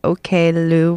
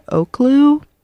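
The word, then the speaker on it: Oklou: I literally found out like last week that OK Lou was pronounced like that instead of Oak Lou.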